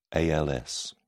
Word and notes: als: British English

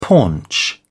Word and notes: paunch: British English

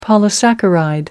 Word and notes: polysaccharide: American English

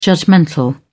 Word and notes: judgmental: British English

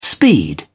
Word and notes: speed: British English

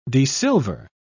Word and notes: desilver: British English

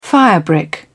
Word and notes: firebrick: British English

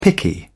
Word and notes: picky: British English

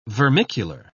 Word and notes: vermicular: British English

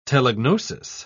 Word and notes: telegnosis: British English

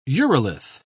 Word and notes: urolith: British English

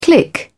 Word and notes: click: British English

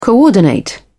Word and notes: coordinate: British English

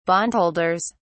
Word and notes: bondholders: British English

